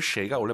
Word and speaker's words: 谁: 간혹가다 근데 중국인들이 선생님 이 谁 발음을 제가 이제 무슨 말 할지 아실 것 같아요. 쉐이라고도 막 하죠. 그죠? – 예,可以다. – 정확한 발음은 쉐이가 원래 맞습니다.